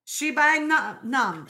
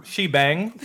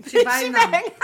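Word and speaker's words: Sheba-nam: Shibanam. – Shibang? – Shibanam. No, Shibanam.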